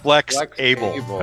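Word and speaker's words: Flexable: The word flexible does not have a hyphen in the middle of it. How's it pronounced? – It's flexible. – Flex-able. – That's right.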